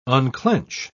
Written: unclench

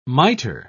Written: mitre

